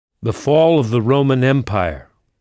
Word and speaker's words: Roman: Et romain se dit Roman. C'est presque identique au français, mais sans le i. Roman, R-O-M-A-N.